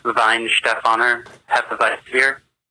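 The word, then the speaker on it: Weihenstephaner Hefeweizenbier: It's actually pronounced Weinstephaner Hefeweizenbier.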